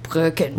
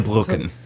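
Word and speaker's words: Brücken: Claudia, you have to pronounce it like Brucken. – Right. Claudia Brucken.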